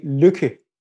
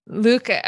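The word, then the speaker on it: Lykke: but the little book of Leike, am I close? – You're close. Okay. We can get closer if we say Lücke. – Lücke. Okay. Perfect.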